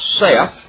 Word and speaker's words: saith: Wherefore he saith," and the language scholars tell us that that word S-A-I-T-H is properly pronounced Seth, like the name Seth.